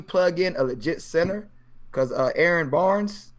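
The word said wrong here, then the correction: Baynes: Baines, whatever you say his name. Baines, he's trash.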